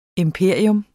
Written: IMPERIETS

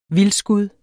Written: vildskud

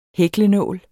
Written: hæklenål

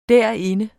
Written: derinde

